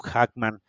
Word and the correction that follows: Jackman: siempre lo pronuncio mal. – Eh, Hugh Jackman.